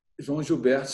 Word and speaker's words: João Gilberto: João Gilberto, sem compromisso.